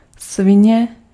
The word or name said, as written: fils de pute